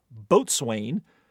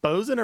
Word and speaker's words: boatswain: which landlubbers mispronounce as boatswain, or what rather economical 5-letter word that is pronounced the correct way. – I usually get it wrong. I'm going to say it wrong. Is it bosun or bosun? – Bosun. – It's bosun. Bosun. Yeah, bosun is fine. But you don't want to say boatswain.